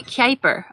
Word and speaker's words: Kuiper: Like the Cooper belt. We have a belt to stuff. – Kuiper. Okay, so it might be pronounced Kuiper. Well, that would make sense. If it's pronounced Kuiper, like the Kuiper belt, then it would make sense.